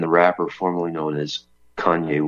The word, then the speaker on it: Kanye: or Kane West, as some want to pronounce it, at Trump's Florida estate. So Nick made the news. – Yeah. Well, we got one particular boomer to manage to stop saying Kane West and start saying Kanye.